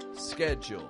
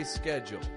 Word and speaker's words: schedule: In my accent, we'd say schedule. In other accents, it may sound a little more like schedule, making a sh, schedule. But not where I'm from. Where I'm from, we'll say scheduled. So what's the difference here, and how does this apply to you? Well, the spelling of this word is S-C-H-E-D-U-L-E. The American accent pronounces this S-C-H-E like scare, scare, making a k sound, scare.